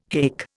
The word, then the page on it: haik: Indian